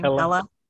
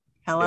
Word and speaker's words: Hela: Hela. – Hela, yeah.